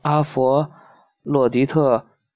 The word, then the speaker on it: 阿佛洛狄特: 这时，第三个女神微笑着开了口，说：“ 我是阿佛，嗯，这怎么念？” 阿佛洛狄费，呃阿不是阿佛洛狄特，专管爱情的女神。我愿把人间最漂亮的女子送给你做妻子，你会享受到幸福的爱情。阿佛阿佛洛狄特。